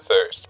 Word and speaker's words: Thrst: T-H-R-S-T. Yeah, it's kind of cool the way it's spelled. I get a lot of people saying first coffee. And after the third time of correcting, I'm just like, yeah, first.